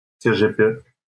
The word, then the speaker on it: CHP: Bom, o, antes das, da corrida, da campanha começar, houve uma disputa interna no CHP, que em turco se pronuncia CGP, que é o Partido Republicano de Esquerda Republicana e é o partido típico da, da República Turca, que é uma república secular.